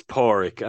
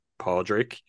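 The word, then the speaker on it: Pádraig: It almost sounds like it's Porig. I mean, okay. That is a name to this day, which, depending on the person, they might pronounce it Podrick, Podrick Harrington, the golfer, for example, or Porig. But in the film, it is Porig that he's referred to.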